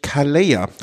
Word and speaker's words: Calella: Die Vuelta führte durch Calella. Das doppelte Ich spricht man im Spanischen und Katalanischen plattgesprochen als J aus.